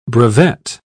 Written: brevet

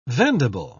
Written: vendible

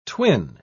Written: twin

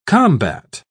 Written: combat

